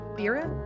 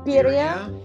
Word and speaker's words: birria: B-I-R-R-I-A. Look at the two of them. How do I pronounce that? – Birria, I think. – There we go. Tacos. Birria. – Sure. – Am I saying it? I don't want to bash. I want to, I want to say birria, right? – Birria. – Birria. – Yeah. – B-I-R-R-I-A. Is that right? – Yeah.